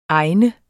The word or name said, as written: egen